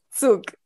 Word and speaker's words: zouk: And then moved on now to learning souk, which is another way of expression, really moving other parts of the body. I didn't know that one can move. – And what is it called? Souk? – Souk, yeah. – How do you spell it? – Z-O-U-K.